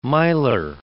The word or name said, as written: miler